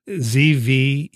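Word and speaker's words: Zvěřina: And I'm going to mispronounce his name, but Halleck's book is dedicated to Dr. Joseph Zervina, Zverina, 1913 to 90.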